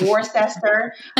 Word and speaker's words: Worcester: Yes, it's pronounced Worcester, not Worcester.